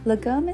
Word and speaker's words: lagom: Legom is spelled L-A-G-O-M and is a Swedish word that, similar to the Danish word hygge, does not have a direct English translation.